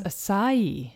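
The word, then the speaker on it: açaí: A-C-A-I. – Acai? Is that what you're It's acai. – Acai, oh. – And I saw so many different pronunciations of this. So some people will say acai, acai, or just acai, but you actually stress the last syllable of E. So it's acai.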